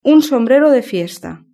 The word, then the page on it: un sombrero de fiesta: Spain Spanish